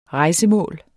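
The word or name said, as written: REJSEMÅLET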